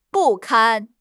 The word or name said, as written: 不堪